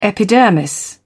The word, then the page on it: epidermis: British English